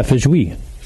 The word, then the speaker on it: feijoa: So Just for Funk is our golden sour beer aged with Feijão? I don't really know how to pronounce it. – Spell it. – F-E-I-J-O-A. – Yeah, Feijão. – Fucking nailed it, yeah.